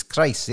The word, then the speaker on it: Kruis: I keep saying Kruis, and I remember you saying to me when I asked you how you pronounced your surname, it's Kruis. It rhymes with grace.